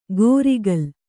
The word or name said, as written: ಗೋರಿಕಲ್